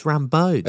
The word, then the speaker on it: Rambeau: It's Rambeau. Is it? You don't pronounce the N.